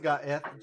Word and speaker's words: Gaethje: It's pronounced Gaethje. – That's another name that you're not hearing. About is Justin Gaethje.